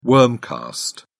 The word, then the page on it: worm cast: British English